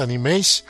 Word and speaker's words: Animage: Por un lado está la primera que surgió en 1978 de editorial Tokuma Shoten y me refiero a Animage, Animage, Animage, Animash. Yo creo que la pronunciación correcta es Animage.